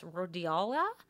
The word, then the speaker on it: rhodiola: And then the last one called, how do you say this, rhodiola? – Perfect, you nailed it. Yeah, you definitely got it.